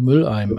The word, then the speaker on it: Mülheim: Mülheim. – Das H ist ja nicht. Also Mülleim eigentlich. – Wird nicht ausgesprochen.